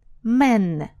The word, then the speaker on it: men: Во множественном числе поменяет звук на закрытый «э». «Мен» растянули губки и не открываем рот широко.